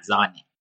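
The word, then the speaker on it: Sané: توی آلمان سانه میگن زانه خب. خب به خاطر اینکه تلفظ آلمانیش میشه زانه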